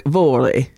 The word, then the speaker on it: Vore: I'm saying Vore, but I think it's Vore. Like, Vore is how he says it.